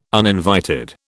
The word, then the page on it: uninvited: British English